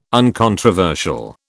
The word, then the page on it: uncontroversial: British English